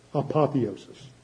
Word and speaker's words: apotheosis: Don't confuse that with pagan views of things, apiathosis. I'm not saying it right, where the spirit gets glorified. This is talking about resurrection. Different concept. – Apotheosis. – Thank you. Every time I say it wrong, and I have to ask to help me say it right.